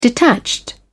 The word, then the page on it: detached: British English